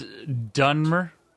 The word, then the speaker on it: Dunmer: There'll be a dumber sorcerer by the name of Vinal. – It's Dunmer. – It's what? – It's Dunmer. I'd appreciate it if you pronounce my race appropriately.